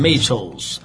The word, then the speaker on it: Maytals: É assim que fala, né? Maytals. Se escreve M-A-Y-T-A-L-S.